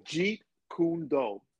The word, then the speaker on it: Jeet Kune Do: Jeet Kune Do? What is it? – I like that. That was good. It's Jeet Kune Do.